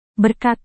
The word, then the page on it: berkat: Indonesian